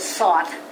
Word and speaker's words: sort: Okay, so if you want to do a sort, and I pronounce that as sort, but I try very hard to put the R in because years ago people asked me what a sort was. So it's an S-O-R-T.